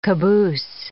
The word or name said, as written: caboose